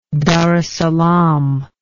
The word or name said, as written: dar es salaam